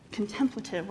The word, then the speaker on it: contemplative: Like, I always say that, put the emphasis on the wrong contemplative worship.